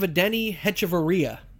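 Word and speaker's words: Adeiny Hechavarría: I mean, they're so high on Willy Adames, they got rid of Adeni Hechavarria.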